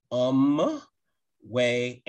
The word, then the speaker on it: Anmwe: The name of this veteran-owned company is pronounced Um Way Anime. That first word is spelled A N M W E.